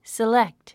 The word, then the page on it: select: silékt; セレェクト